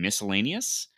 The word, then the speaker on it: miscellaneous: Um, and and look at uh Michigan from their offense, their defense, and then um some some miscellaneous stuff. I pronounced the C in miscellaneous.